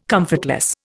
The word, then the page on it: comfortless: Indian